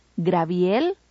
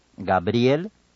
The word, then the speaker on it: Gabriel: También evitemos decir diabetes, Graviel o Joaquín. – Pronunciemos entonces diabetes, Gabriel, Joaquín.